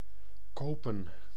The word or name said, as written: kopen